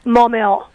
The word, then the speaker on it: Maumelle: Maumelle. – Maumelle, Arkansas.